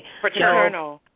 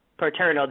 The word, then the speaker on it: Paterno: I can never say his name. – Paterno. – Paterno.